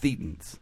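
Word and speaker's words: thetan: It's pronounced thetans.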